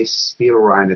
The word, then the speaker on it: Spielrein: Not only sex that is imputed to Jung and Spielrein. And by the way, if I say Spielrein, it's because I'm American. The actual way to pronounce her name is Spielrein.